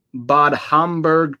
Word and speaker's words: Bad Homburg: And then it's Bad, B-A-D. So it's not bad. It's Bad. Bad Homburg, H-O-M-B, okay. Like burger, Homburg.